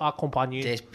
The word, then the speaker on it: accompagné: Y como yo no sé pronunciar francés, pero pone así como So accompagné.